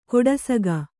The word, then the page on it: ಕೊಡಸಗ: Kannada